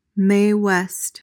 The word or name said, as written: Mae West